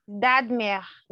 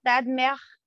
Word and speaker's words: Dodmer: Dodmer, with the rolled R, Dodmer.